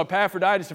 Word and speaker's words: Epaphroditus: You don't have to know how to spell that in order to get to heaven, but you do need to know how to pronounce it, so learn it. It's Epaphroditus. Anyway, so Epaphroditus in Philippians 2 went on a mission trip.